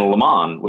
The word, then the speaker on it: Le Mans: And Le Mans, with that silent S, the Rolex marketing department, as small as it was in 1963, said, you know what, that could turn off the American market.